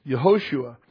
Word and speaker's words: Yehoshua: Or at least it was changed before that, because Moses called him Yehoshua. The accent on the shu. Yehoshua was his what Moses called him in Numbers chapter 13, verse 6, meaning Jehovah has saved.